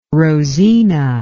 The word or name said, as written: rosina